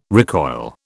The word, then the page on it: recoil: British English